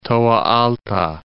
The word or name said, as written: Toa Alta